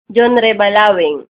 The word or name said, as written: Balawing, Junrey